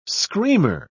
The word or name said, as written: screamer